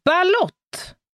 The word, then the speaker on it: balut: Balott eller balut. Balut.